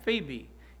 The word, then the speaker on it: Phoebe: Now, some people spell it P-H-E-B-E. Some people put an O in it. But either way, it's pronounced the same, Phoebe.